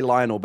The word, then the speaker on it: Lionel: It's spelled like you're going to say Lionel, but it's Lionel.